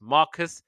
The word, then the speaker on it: Marquess: The Marquess portion of his name is pronounced Marcus and rendered as if it is his actual name instead of a title.